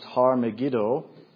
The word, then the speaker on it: Har Megiddo: The Hebrew would be pronounced Har Megiddo.